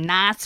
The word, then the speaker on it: Nice: Did I say that right too? – Yes. – Okay, thank you. – I think. – I'm pretty sure. – Yeah. – Because it's not nice, because it's French.